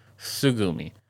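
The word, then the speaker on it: Tsugumi: It was written by Tsushigomi Oba. I believe that's how you pronounce his name. Oba. Or Tsugomi Oba. My bad. Tsugomi.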